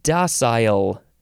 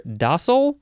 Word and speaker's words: docile: Docile is next, and you can pronounce it either docile or docile. Docile. I think I usually say it with a long I sound. Oh, the British say docile.